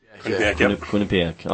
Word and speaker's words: Quinnipiac: They are in East Lansing the 14th of March, and that'll be their opener against Quinnipiac? – Quinnipiac.